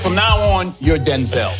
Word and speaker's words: Denzel: My name's not pronounced Denzel. My name's pronounced Denzel.